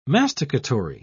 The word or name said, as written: masticatory